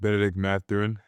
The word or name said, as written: Bennedict Mathurin